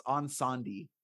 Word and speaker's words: Incendies: How do you say that? – Is it Incendies? – Yeah. I've not. – You know what? By the way, I looked it up. I actually looked it up. It's Incendies is how it's pronounced.